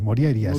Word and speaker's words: Morjaria: Bueno, la J normalmente se pronuncia como I, Moriaria.